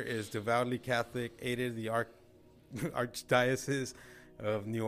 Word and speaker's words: archdiocese: How do you say it? Archdiocese? – Archdiocese.